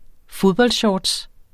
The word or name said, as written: fodboldshorts